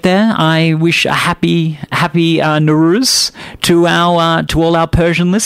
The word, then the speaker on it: Nowruz: But just so everyone knows, I'm going to be collectively calling it Nowruz.